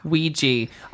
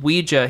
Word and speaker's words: Ouija: Ouija. I looked it up. It is pronounced both ways.